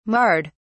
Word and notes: marred: British English

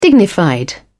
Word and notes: dignified: British English

